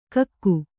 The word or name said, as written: ಗುಕ್ಕ